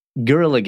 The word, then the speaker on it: Guerrilla Games: But anyway, Guerrilla Games, as I can now say, I can say that correctly, basically.